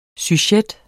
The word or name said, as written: sujet